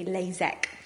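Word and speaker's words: LASEK: First of all is the LASIK, which is spelt with an IK, and the other one sounds exactly the same, but it's spelt with the EK, LASIK.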